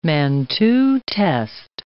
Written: Mantoux test

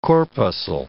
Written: corpuscle

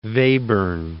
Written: Webern

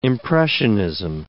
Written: impressionism

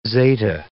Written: zeta

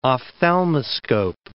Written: ophthalmoscope

